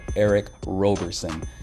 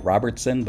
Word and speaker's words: Eric Roberson: One of the standout songs on that mix is a tune called Lessons by Philadelphia soul singer Eric Roberson. At first glance, it looks like Robertson, but that's not how you say his name, ladies and gentlemen. It's Eric Roberson.